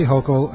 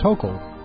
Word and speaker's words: Hochul: Kathy Hochul, and you pronounce it like local, so come on, that's easy. So just think, you know, Kathy Hochul, except she's not local, she's Hochul.